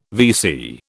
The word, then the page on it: VC: British English